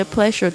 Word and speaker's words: pleasure: 我觉得就是那 guilty pleasure 可能有一点比较难去翻译它，但是呢这个在英文在聊天的时候是有时候会用到的 guilty pleasure，大家稍微注意一下这 pleasure，特别注意一下它那个发音 SU 的发音是发的这个 R 的发音，嘴巴稍微嘟起来 R，然后呢这个发出的这个声音呢是 R， OK， pleasure 不是 sh 的声音 R，有声的这个 R。